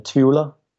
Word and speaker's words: Tvula: I sing in Tvula, which is a really strange name to pronounce in English. – Tvula. – Yeah, Tvula, yeah.